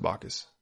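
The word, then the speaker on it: Backus: He pronounced it Bacchus.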